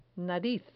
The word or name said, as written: nariz